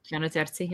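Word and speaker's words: Gianna Terzi: From Yana Terzi? I'm not sure how to pronounce that. – Yana Terzi, yeah.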